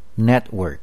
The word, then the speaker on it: Network: 最后一种，当 T 的后面接着子音的时候，会变成省音或者顿音，整个音已经几乎听不见，感觉很像台语的 bit 或者 did 的尾音。例如 Network 网路 Atlas 地图集 Setback 挫折，千万不可念成 Network Atlas Setback。